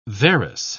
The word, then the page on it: varus: British English